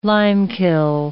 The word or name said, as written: limekiln